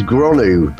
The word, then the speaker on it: Gronw: Now his name is Gronu, but it's spelled G R O N W.